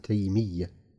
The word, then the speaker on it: تيمية: والياء النسبة مخففة. ابن تيمية. ولا ياء كياء كيا الكرسي زيدت للنسب. – ابن تيمية.